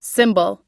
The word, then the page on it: symbol: i